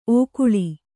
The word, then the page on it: ಓಕೊಳ್ಳು: ōkoḷḷu